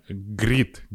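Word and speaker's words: Grit: Grid, G-R-I-T, B-X-N-G.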